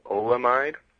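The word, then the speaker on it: oleamide: One notable compound that was not a synthetic cannabinoid, it was recently found on Spice, is, I'm not sure if I'm going to say this correctly, but it's pronounced olamide.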